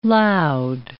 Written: loud

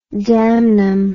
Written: damnum

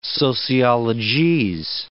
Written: sociologese

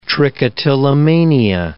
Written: trichotillomania

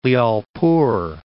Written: Lyallpur

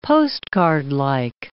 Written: postcardlike

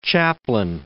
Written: Chaplin

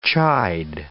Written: chide

